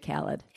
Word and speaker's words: Khaled: Although DJ Khalid? – Yeah. – Okay. – Good job. – Has made No, I think it's DJ Khaled. – Is it Khaled? – It is Khaled, yeah.